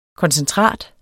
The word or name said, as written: koncentrat